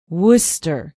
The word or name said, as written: worcester